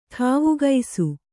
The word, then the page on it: ಠಾವುಗೈಸು: kannada